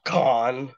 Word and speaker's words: Cannes: I guess like picked it up after Cannes, I believe. – Yeah. – Or Sundance, one of the larger. – Or as some festivals. People pronounce it, con. – Oh, cons! Thank you for catching me on that.